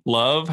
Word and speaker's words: Lauv: And then I'm going to go see, you know, Lauv, LAUV, Love.